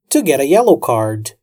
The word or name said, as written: to get a yellow card